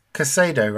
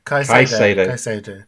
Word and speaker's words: Caicedo: Because it's Caicedo, right? – Caicedo.